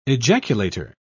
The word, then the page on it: ejaculator: British English